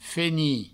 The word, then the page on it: fenir: feni; Provençal